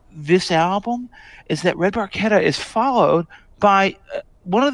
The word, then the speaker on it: Barchetta: It's Barchetta. That's how it should be pronounced in Italian. But of course, these guys are all Canadians, and so like Barchetta to them. No, it's Red Barchetta. It's like a barca.